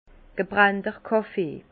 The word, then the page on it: gebrennter Kàffee: Alsatian; Bas-Rhin; Herrlisheim